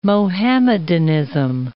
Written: Mohammedanism

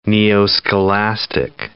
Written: neoscholastic

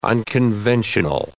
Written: unconventional

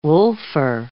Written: wolfer

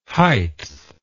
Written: heighth

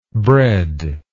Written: breadlessness

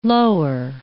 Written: lower

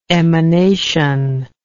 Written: emanational

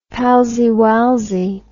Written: palseywalsey

